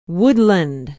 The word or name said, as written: woodland